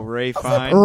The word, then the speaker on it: Ralph Fiennes: How's that pronounced? – Oh, Ray Fiennes. – Ralph Fiennes. – Ralph Fiennes. – How it should be said.